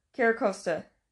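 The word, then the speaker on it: Carracosta: And, geez, I'm going to butcher this last one. Caracosta? – Yeah. – Caracosta.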